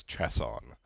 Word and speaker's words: Chiasson: Yeah, so I, you know, the way I was raised pronouncing my last name is Chiasson. You know, like the Chiasson. Game of chess.